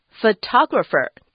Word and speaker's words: photographer: 但是 photographer, 这个字的重音是在 T-O, 要念 ta 这个地方，而且也不能念成 photographer, 要念成 photographer 才对哦。